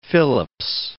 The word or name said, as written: Philips